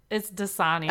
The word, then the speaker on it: Dasani: And Aquafina, I never pronounce this water company right. Dasmani? – It's Dasani. – Oh, Dasani. Oh.